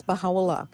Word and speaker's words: Baha'u'llah: So can you tell me a little bit more about Baha'u'llah? Did I say that correctly? – Yes, Baha'u'llah. Baha'u'llah is Arabic, and it means Baha is glory, and Allah is of God.